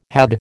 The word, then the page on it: had: American English